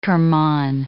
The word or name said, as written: Kerman